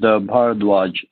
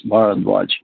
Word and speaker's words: Bharadwaj: It's pronounced Bharadwaj, three syllables, Bharadwaj.